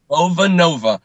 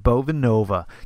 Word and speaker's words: Bovanova: Bovina, am I pronouncing that right? – Bovanova, kind of like Bossa Nova. – But that's right. – Style. – Sorry, man. It's right in front of me. Bovanova.